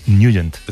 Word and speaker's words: Nugent: Hombre, siempre lo hemos llamado en el barrio Tetnagent o Tetnagent. – Claro, yo era de Tetnagent. – Pero la pronunciación correcta es Nugent. – Te fuiste a Washington y descubriste que era Nugent.